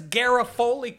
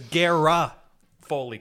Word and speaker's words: Garofoli: But where does Garofoli come from? There isn't even a... – So I know that this is a pet peeve of yours. So in an attempt to... – Look how it's spelled. There's one A. So where does Gara Foley come from? Where do you get it from?